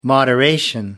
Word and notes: moderation: American English

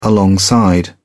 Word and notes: alongside: British English

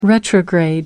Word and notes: retrograde: American English

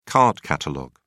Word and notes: card catalogue: British English

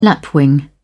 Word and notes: lapwing: British English

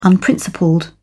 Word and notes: unprincipled: British English

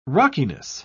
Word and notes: rockiness: British English

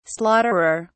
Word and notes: slaughterer: British English

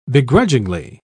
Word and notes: beguilingly: British English

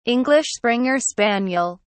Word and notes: english springer spaniel: British English

